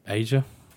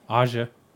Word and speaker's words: Asia: It's not Asia. – That's how it's fucking spelled. I mean, you could say Asia. – I think that's what they pronounce. I can't remember. – I mean, if you want to go fucking pronounce every freaking syllable of it, or every letter, Asia. Asia. – What was it? – Aja. – Aja, I think it's Aja. – Yeah, Aja, but it's spelled Asia.